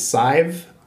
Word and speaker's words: Sadhbh: Um, I also have a, a, sister whose name is Sive, and it's spelled S A D H B H.